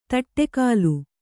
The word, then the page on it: ತಟ್ಟೆಕಾಲು: taṭṭe kālu